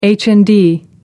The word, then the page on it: HND: American English